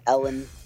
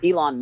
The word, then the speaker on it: Elon: I've been saying Ellen as long as I can remember. But Elon Musk, basically, he pays for new water filtration system at all Flint schools.